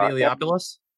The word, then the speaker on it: Eliopoulos: Ted Eliopoulos? – Eliopoulos.